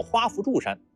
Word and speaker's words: 花不住山: 画左边长得像元宝的这座山叫鹊山，画右边长得像冰激凌甜筒的这座山叫花扶柱山。您可能已经有疑问了，那不是花不住山吗？怎么读成了花扶柱山了呢？因为这里的不原本是夫，有花萼的意思。